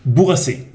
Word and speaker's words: Bourrasse: ¿cómo se pronuncia de forma correcta el nombre de la empresa? Bourgassé.